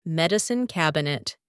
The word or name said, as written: medicine cabinet